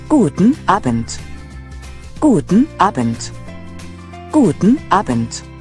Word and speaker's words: Guten: The word for good is spelled G-U-T-E-N. It's like gluten without an L. The word for morning is spelled M-O-R-G-E-N, almost like the woman's name.